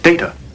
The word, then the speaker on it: Data: Dada, look at this. Dada, look at this. – Data. – What? – My name. It is pronounced Data.